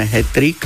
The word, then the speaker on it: hetrik: Dôležité je povedať, že používa sa už v zdomácnenej podobe, teda sa píše len s jedným T, tak ako ho vyslovujeme hetrik.